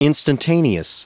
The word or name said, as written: instantaneous